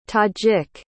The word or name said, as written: tajik